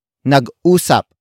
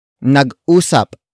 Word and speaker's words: nag-usap: Word for word it's nag-usap sila buong maghapon. Nag-usap sila buong maghapon. Nag-usap sila buong maghapon. The first word is nag-usap. Nag-usap. Nag-usap. Nag-usap is spelled as N-A-G hyphen U S A P.